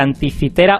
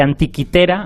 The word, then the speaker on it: Anticitera: Eh, y bueno, el, oyente está hablando de, una, de un dispositivo, un mecanismo, que se llama máquina de anticitera o de antiquitera. Antiquitera es, un, es una pronunciación mejor, más parecida al, original, que es un mecanismo muy antiguo y además creo que Cancho nos ha explicado dónde se encontró y cositas sobre él.